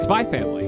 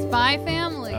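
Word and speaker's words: Spy × Family: I say it, Spy Family. – Spy Family. – Okay. – With a silent X.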